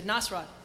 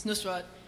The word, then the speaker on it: Nusrat: Because I have a friend named Penny, and I played her the thing, and she said Nasrat. – Yeah. – It's Nusrat, and he's my Elvis.